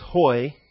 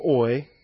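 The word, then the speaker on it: hoi (οἱ): That little accent mark on top is your H. So that's hoi. Just tells you that it's hoi instead of oi.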